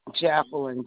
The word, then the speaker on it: Jacqueline: Jacqueline, J-A-C-Q-U-E-L-I-N-E.